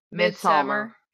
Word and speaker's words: Midsommar: So Midsommar. – Yeah. it means midsummer, but it's... – No, it's... I was reading a bunch about this. It's actually midsummer. And the D is silent.